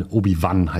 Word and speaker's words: Obi-Wan: Luke Skywalker wird aus seiner Welt rausgerissen, muss die Prinzessin befreien, findet sogar einen älteren Mentor, Obi-Wan Kenobi, der ihn dann, Obi-Wan heißt das, ne? Genau, der ihn dann, sollte jemand, der Leute dafür kritisiert, dass sie Paul und nicht Pell sagen, sollte Obi-Wan Kenobi richtig aussprechen.